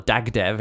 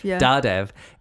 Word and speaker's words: Dardev: or Dagdev, if you don't know how to pronounce his name properly. – Dardev is a professor, and back on Earth, he's a pretty good professor, like pretty well done.